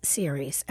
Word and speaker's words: Ceres: I went to a fundraiser on Saturday night with my friend for the Ceres Foundation. And I've been pronouncing the word C-E-R-E-S, Ceres. It's Ceres.